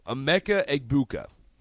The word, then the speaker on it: Egbuka: Emeka Egbuka from Ohio State.